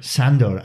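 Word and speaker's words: Szandor: Los principios fundamentales de la Iglesia de Satán fueron explicados por su fundador, Anton es que no sé pronunciar eso, Snazor LaVey. – Sandor.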